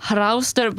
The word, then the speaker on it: hraustur: Hraustur? Is that how you say it? – Hraustur, but again, my Old Norse is a bit rusty.